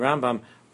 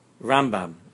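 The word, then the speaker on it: Rambam (רמבם): Since in Pnimius, the Rambam is Chachma, Chachma is the right side, the Pnimius of Malchus is Chachma, the right side, therefore we say the name Rambam, Rambam. Rambam, Mil-El, with the Dogesh, the emphasis on the right side of the name, because he comes from the right side.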